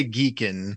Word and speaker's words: Geacon: Is it a hard G or a soft G? – I'm going to assume that this is probably to be pronounced Geacon, because it's a Go language-based beacon. So I'm gonna say Geacon.